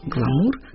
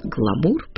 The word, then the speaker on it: гламур: В русский язык слово «гламур» пришло из английского, но явно через французское посредство, о чем говорит произношение его с ударением на последний слог.